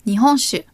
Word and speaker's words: 日本酒: And shu is the Chinese pronunciation of sake. Remember the rule of thumb? If you have a word consisting of one kanji, then you use the Japanese pronunciation, that's sake. If you have a word consisting of several kanji, then you use the Chinese one, and that's in this case shu. Nihon shu, Japanese alcohol, means rice wine.